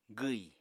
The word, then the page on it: gwi: apatani